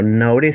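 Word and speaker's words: Gnahoré: Ella es la marfilense Dovet Naoré su apellido, Nauré, se escribe G-N-H-O-R-E.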